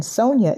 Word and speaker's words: Sonya: And Sonya is spelled S-O-N-Y-A.